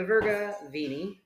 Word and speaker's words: Verga Vini: So anyway, the Virga Vini. See, I said it right that time.